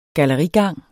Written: gallerigang

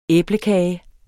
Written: æblekage

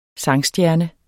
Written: sangstjerne

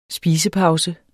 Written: spisepause

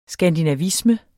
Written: skandinavisme

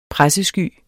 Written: pressesky

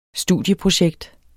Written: studieprojekt

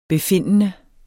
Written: befindende

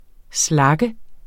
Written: SLAGGERNE

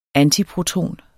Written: antiproton

